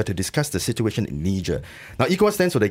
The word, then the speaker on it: Niger: It's Niger.